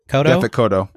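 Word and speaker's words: Yaphet Kotto: Yafet Kodo.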